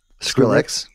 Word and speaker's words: Skrillex: Skrillex.